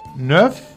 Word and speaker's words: neuf: Escucha también alumnos y profesores a decir neuf. No es neuf, c'est neuf. N-E-U-F.